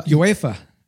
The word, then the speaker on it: UEFA: UEFA.